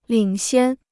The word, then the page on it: 领先: lǐng xiān